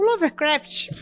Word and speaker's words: Lovecraft: Lover. Porque ele é um amante. Como é que fala essa porra? – Love. Pensa Lovecraft. Pensa no Wagner Love. É, pensa Wagner nele Love.